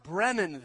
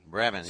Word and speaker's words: Bremen: So it's pronounced Bremen, then. – Bremen.